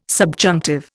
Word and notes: subjunctive: Indian

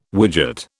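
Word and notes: widow: British English

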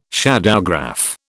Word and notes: shadowgraph: British English

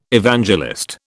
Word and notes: Evangelist: British English

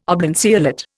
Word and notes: oblanceolate: Indian

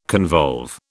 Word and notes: convolve: British English

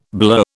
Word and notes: blur: British English

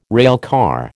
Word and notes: railcar: American English

